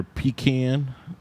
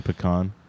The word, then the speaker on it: pecan: Definitely going either pecan, I mean pecan, because that's the correct way to say it, pecan or oak.